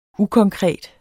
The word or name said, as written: ukonkret